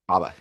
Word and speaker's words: Baba: Would you have preferred Butler back or do you like is it Baba? Am I saying that right? Baba, yeah. Baba yeah.